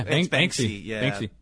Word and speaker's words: Banksy: Am I saying that right? Banksy? – Yeah, Banksy.